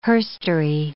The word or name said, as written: herstory